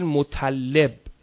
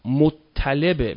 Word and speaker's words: مطلب: مطلب بعضی ها اشتباهاً تلفظ میکنن میگن مطلب، مطلب غلطه، مطلبه، مثل مطلع.